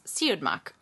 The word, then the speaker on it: Siodmak: So that's how I learned how to pronounce Siodmak.